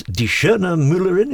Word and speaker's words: Die Schöne Müllerin: Well, you've been very kind, Bridget, doing all the foreign pronunciations, but it's Die Schöne Müllerin, is that right? – That's correct, yes.